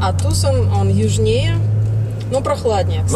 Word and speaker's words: Тусон: Или Тусон, как вы произносите? – Тусон.